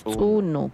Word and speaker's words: tsunu: Tsunu. Colibrí se pronuncia tsunu y se escribe con las letras ya mencionadas, tomando en cuenta que lleva el apóstrofe, que le da un tono diferente, porque si no le agregamos el apóstrofe, diría suno o suni.